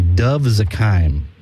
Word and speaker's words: Dov Zakheim: But who is, and I hope I'm pronouncing this name correctly, Dov Zakheim? Am I pronouncing that correctly? – Yeah.